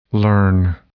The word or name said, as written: learn